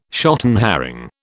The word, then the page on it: shotten herring: British English